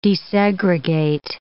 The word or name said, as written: desegregate